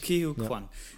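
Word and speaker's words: Ke Huy Quan: Kehui Kwan.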